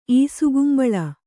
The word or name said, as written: ಈಸುಕುಂಬಳ